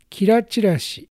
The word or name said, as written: Kirachirashi